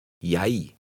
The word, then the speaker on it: jeg: Notice that an initial J is pronounced like an English Y, as in yes. – Jeg.